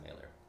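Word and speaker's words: Mahler: I don't want to say it, but I believe it's pronounced Mailer. So it's Mailer.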